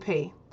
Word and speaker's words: Piye: Number two, one of the most famous leaders of Kush was King P. That's spelled P-I-Y-E.